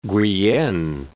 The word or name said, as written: Guyenne